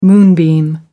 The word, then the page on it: moonbeam: British English